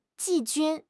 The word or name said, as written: 季军